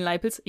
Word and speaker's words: Leipel: Da finde ich es jetzt nicht, weil das, es steht kein guter Stern über den Leipels. Ich habe übrigens hier manchmal geschrieben Leipelz, aber er heißt eigentlich Leipel.